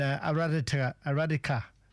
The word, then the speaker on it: Aradika: And Aradika is the pronunciation. Aradika, Rise Up is the meaning, and Shawnee Chulbra is acting in as well and presenting.